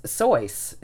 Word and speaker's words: Seuss: and it was Soyce. It was pronounced and that's what it should have been. And everyone called it Seuss because of how it's spelled.